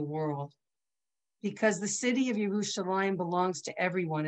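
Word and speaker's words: Yerushalayim (ירושלים): We pronounce it Yerushalayim even though it's Yerushalayim.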